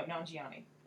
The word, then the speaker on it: Nanjiani: No, Nanjiani. – Okay. – Because it rhymes with Poonani.